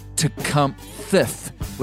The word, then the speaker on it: Tecumseh: But the pronunciation of his name is elusive. They say that it was probably closer to Tecumtheth with a thith on the end of it, which is odd to our ear.